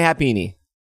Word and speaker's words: Happiny: No, it's definitely Happiny.